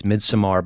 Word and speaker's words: Midsommar: Is it Midsommar or Samar? – I Googled it or YouTubed it, and I believe the director said it's Midsommar, but he seemed like he was pretty flexible with the pronunciation.